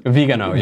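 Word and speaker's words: Viganò: Vigano, yeah.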